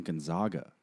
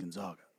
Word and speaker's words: Gonzaga: And everybody was calling them Gonzaga.